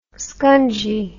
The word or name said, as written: scungy